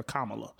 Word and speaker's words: Kamala: It's a whole shirt out there that says it has a comma and then an L.A. for Kamala, so people know how to pronounce her name.